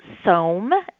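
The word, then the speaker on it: Som: and we welcomed a female named Soam. It's S-O-M, but it rhymes with foam or Rome, and she is 3 years old, and she is settling in really well.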